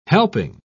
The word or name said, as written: helping